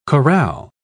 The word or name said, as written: chorale